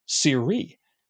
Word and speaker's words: Siri: It's not pronounced like the iPhone lady. It's actually Siri, which is interesting to me.